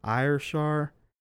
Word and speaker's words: Ayrshire: Ayrshire, along with a small army of 400 armed men and a pack of tracker dogs.